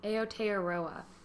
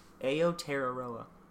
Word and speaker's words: Aotearoa: Aotearoa. – Aotearoa. That's hard to say. – It's written very phonetically because of the limitations of the English alphabet. – Aotearoa.